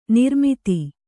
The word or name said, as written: ನಿರ್ಮದೆ